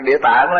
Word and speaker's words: Địa Tạng: Đọc là Địa Tạng là chữ tàng, là chữ tạng.